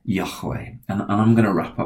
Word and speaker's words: Yahweh: The Hebrew word for the name of God is Yahweh. And we add the vowel sounds in. There's no vowel sounds in the original Hebrew. It's just four letters.